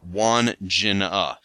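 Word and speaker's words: Wandjina: Says, just to chime in on the Aussie pronunciation of Wandjena, I've mostly heard it said Wandjena.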